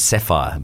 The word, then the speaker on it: Saffire: Their name is Sapphire, but you spell it S-A-F-F-I-R-E.